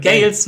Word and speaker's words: Eric Gales: Und ich muss dir unbedingt ihm deinen Kontakt geben, weil du wolltest ja mal diesen Eric Giles Amp ausprobieren. – Ja, von dir. Gales. – Eric Gales wird's ausgesprochen. – Er wird tatsächlich Gales ausgesprochen, ja.